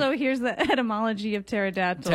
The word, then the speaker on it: pterodactyl: I bet you pterodactyl wings would taste amazing. – Remember, the P is silent in pterodactyl. – P-T-E-R-A.